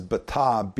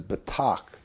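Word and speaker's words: batach (בָּטַח): The most common word used is the word, and Bullinger says batah, B-A-T-A-H, but in Young's it says batach, B-A-T-A-C-H, which I think is really how they pronounce it. So they did a phonetic spelling.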